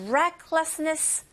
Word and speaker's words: recklessness: It's, 또 역시, its recklessness, reck, 발음이 R 소리로 시작되기 때문에 렉이 아니고 여러분 recklessness makes the journey more worthwhile.